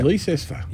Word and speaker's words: Leicester: And we've just sold 68 Leicester Street, however you want to pronounce it. – Yeah, well, Leicester, some people. I reckon it's Leicester. – Yeah, exactly. – If you go to England, it's actually Leicester.